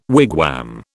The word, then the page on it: wigwam: British English